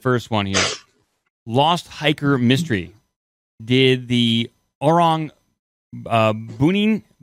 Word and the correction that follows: Orang Bunian: So it is pronounced the Orang Bunian, Bunian, Orang Bunian.